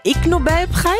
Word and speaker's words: Ig Nobelpreis: Ist es der Ig Nobelpreis? – Ich glaube, IG. – Ihr versteht schon. – Ja, ja. – Er wird im Englischen wie ignoble ausgesprochen, ja, was auf Deutsch so viel wie unehrenhaft oder unwürdig bedeutet.